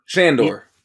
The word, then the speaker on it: Shandor: Shandor.